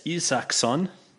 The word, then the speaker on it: Isaksson: So, Andreas Isaksson. – Mm-hmm. – This they phonetically pronounce this thing as E-E-S-A-K-S-O-N. So, Isaksson.